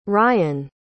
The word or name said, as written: rayan